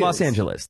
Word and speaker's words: Los Angeles: It's Los Angeles.